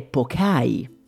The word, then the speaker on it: poke: Il poke o poke nasce agli Hawaii e infatti si dovrebbe pronunciare pokai per essere fedeli alla lingua originale.